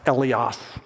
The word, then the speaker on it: eleos: And by far, the most common one is this top word, and that's pronounced eleos.